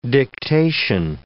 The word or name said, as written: dictation